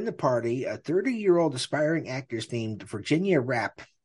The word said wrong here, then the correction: Rappe: Did I say that right? Rappe. – Rappe. – Like frappe. Okay.